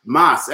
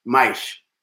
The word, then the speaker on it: mais: No, because Portuguese, mas, M-A-S is Spanish, mas. Portuguese, mais, is how you say mais, is how it's pronounced, mais que nada.